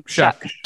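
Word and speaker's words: Shough: So if Oregon State wants to win, they need to contain Tyler, is it Shaw? Shaw? I don't want to mispronounce his name. – Shuck. – Tyler Shuck. With Duck.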